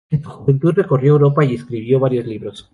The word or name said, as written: juventud